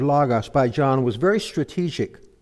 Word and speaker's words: logos: And we saw that the Greek word translated Word is the word logos, L-O-G-O-S, pronounced logos.